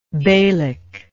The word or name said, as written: beylic